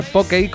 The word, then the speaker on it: Pokey: En fin, para que lo quiera buscar, Pokey, como si fuera póker, y acabado en Y, Lafarge, se escribe con G.